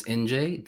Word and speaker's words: Injae: My name is Injae. That's how you pronounce it. It's if you have problems with the pronunciation, think New Jersey, NJ.